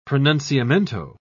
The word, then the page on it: pronunciamento: British English